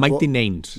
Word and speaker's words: Mighty Nines: Porque digamos que este grupo de Mighty Nines va a funcionar como un grupo... – Mighty Nines. – Nines, perdón, perdón mi pronunciación anglosajona y no alemana.